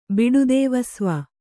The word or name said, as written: ಬಿಡುದೇವಸ್ವ